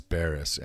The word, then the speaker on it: Barrus: Barrus. – And it's like, but it's spelled B-O-U? – B-A-R-R-U-S.